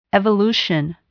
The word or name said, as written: evolution